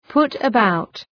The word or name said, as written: put about